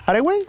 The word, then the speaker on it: Hathaway: Y nos aprobaron y estuvo en Hathaway. ¿Hathaway? – Sí, se pronuncia así, actriz, creo.